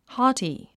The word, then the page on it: haughty: hɔ’ːti; ホウティ